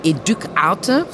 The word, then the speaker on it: Educ'ARTE: Educ Arte, so würden wir es auf Französisch aussprechen, also Education Arte.